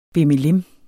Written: bimmelim

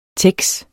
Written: tex

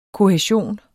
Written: kohæsion

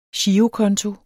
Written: girokonto

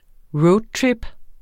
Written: roadtrip

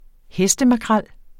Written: hestemakrel

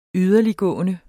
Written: yderligtgående

